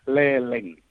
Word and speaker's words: Leleng: Film itu Leleng, Lelung atau Lelung itu? – Cara bacanya ya, cara bacanya adalah Leleng.